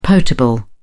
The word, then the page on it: potable: British English